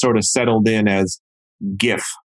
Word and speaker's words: GIF: Now, the inventor, a chap by the name of Stephen Wilhite, insists that the proper pronunciation is GIF, like jelly, not GIF, like giddy.